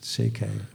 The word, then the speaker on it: C.K.: Er staat Tsekeli, staat er op de cover van die boeken vaak. Het is C.K.